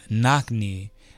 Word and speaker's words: Knockney: That was called Knockney by Supermassive. It's me and Steve. – Is it like regular knock and knee? – Yeah. Yep. Knockney. – And Steve is spelled how? – S-T-E-E-F.